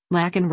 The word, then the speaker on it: Laken: Breitbart News also asked Ogles for his take on Biden's mispronunciation of Laken Riley's name. Riley was a 22-year-old nursing student allegedly murdered by an illegal alien on February 22, and Biden pronounced her name as Lincoln on Thursday amid pressure from Republicans, many of whom wore Laken Riley pins, to address the case.